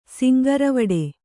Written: ಸಿಂಗಾರವಡೆ

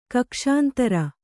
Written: ಕಕ್ಷಾಂತರ

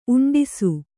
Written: ಉಂಡಿಸು

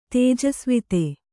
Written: ತೇಜಸ್ವಿತೆ

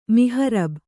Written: ಮಿಹರಬ್

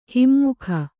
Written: ಹಮ್ಮುಗೆ

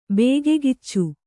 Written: ಬೇಗೆಗಿಚ್ಚು

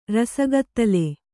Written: ರಸಗತ್ತಲೆ

